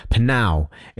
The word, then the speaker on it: Pnau: Panow is how I say it. You know, P-N-A-U. How? What did I say? P-N-A-U. Not P-N-A-U. Yeah, so I think it's Panow.